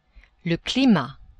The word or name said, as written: climat